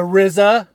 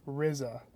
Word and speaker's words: RZA: The RZA. Did I say that right? – I think you did. – Whew. Good thing. I feel like I've mispronounced it in the past in other movies that the RZA has been in. – Although it might just be RZA yeah. – at this point, not the RZA.